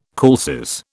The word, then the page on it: calces: British English